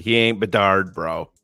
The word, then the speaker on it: Bedard: We quote, we shouldn't have drafted Connor Bedard.